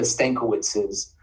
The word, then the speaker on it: Stankiewicz: So like the nickname is not anything new for the Stankiewiczes, or pronounced in Polish, because it is Polish, as Stankiewicz.